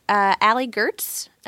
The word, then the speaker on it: Allie Goertz: I tweet on Twitter at Allie Gertz. – At Allie Gertz. – Used to be Cosby Sweater. Now I'm just Allie Gertz. – How do you spell that? – A-L-L-I-E-G-O-E-R-T-Z.